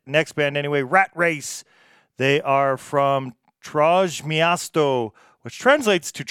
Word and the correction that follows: Trójmiasto: So maybe that's a specific area in Poland that I'm not aware of, but Trójmiasto. – Trójmiasto. So better pronunciation there of the name of that city or area in Poland.